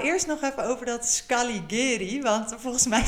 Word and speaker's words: Scaligeri: Eh, dat is waar de Scaligeri, zeg ik het goed, Hazel? – Heel goed De Scaligeri-familie uh, eh, eh, lang gewoond heeft.